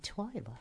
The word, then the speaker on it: Twila: I am Twila.